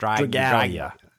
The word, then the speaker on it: Dragalia: Dragalia. – Dragalia Lost World. – Yeah, Dragalia Lost, like dragon, Dragalia.